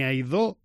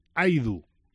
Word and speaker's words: Aidu: Al menos así es como él mismo pronuncia su nombre. Ni Aidó ni Aidú. Es Aidu. Es como el jugador dice que se pronuncia en el vídeo de presentación que ha realizado para los medios del Real Valladolid.